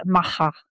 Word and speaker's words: MAGA: I pronounce it MAGA, the Spanish way.